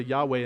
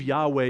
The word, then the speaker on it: Yahweh: And so inserting vowels into that name, we believe Yahweh is how you pronounce it, but we're not 100% sure. We will find out in eternity how to pronounce it.